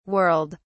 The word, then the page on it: whirled: British English